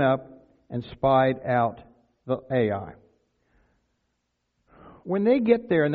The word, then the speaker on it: Ai: Now that's the way I pronounce it. I'm pretty sure there's other ways to pronounce it, but it's just two letters and I just say Ai.